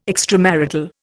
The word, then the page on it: extramarital: Indian